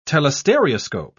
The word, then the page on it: telestereoscope: British English